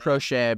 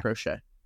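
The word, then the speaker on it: Proche: Andre Roberts and James Prochet, I don't know exactly how you pronounce his name. – Prochet, I believe. – Okay. – Yeah, Prochet.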